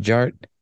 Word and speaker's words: Jart: The D is silent, so it's just Jart.